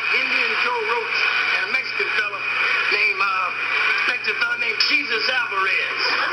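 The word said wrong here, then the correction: Jesús: Hey, that's pronounced Jesus. – Well, he spelled it the same way Jesus spelled his name. – I know, but you don't say it that way.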